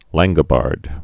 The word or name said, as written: Langobards